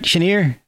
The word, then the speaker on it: Chenier: Did I pronounce that right? Chenier. – Yeah.